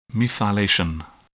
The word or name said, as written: methylation